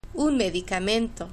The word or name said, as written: un medicamento